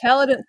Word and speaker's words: Caledon: And then you've got the Caledon Hills. Did I say that correctly? – Yeah, Caledon Hills.